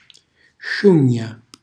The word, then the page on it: sciugna: Calabrese